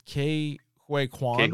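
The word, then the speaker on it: Ke Huy Quan: I don't know how to pronounce his name. Is it Kwe Kwan? – Yeah.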